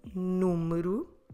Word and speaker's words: número: Notice that that second syllable, mu, it's really soft. Número can easily be pronounced just like número. I mean, completely dropping the E, or in Portuguese, the letter E. So if you hear someone saying número, número, that you can't really hear the vowel, that's a normal way to pronounce it. That happens because the vowel there is unstressed. We're stressing the first syllable, número.